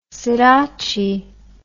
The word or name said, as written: sidotti